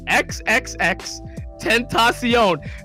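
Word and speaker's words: XXXTentacion: He's like XXX extension. Like, I'm like, bro, like, you could have just asked somebody though, how do you pronounce this dude's name? XXX Tentacion. Like, it's not that hard. Like, XXX Spanish Tentacion.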